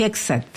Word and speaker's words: Hegseth: Lo que pasa es que con el tema del escudo de las Américas, además, lo que dice Hechter... ¿Lo pronuncié bien? ¿Cuánto es? – Hegseth. – Hegseth. No es Hegseth. – No. – No, Hegseth.